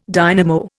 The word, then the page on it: dynamo: Indian